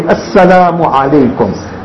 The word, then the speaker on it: Assalamu alaikum: So we should pronounce it properly. Assalamu alaikum.